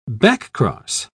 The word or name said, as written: backcross